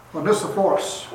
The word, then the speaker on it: Onesiphorus: Onesiphorus, that's how they pronounced it.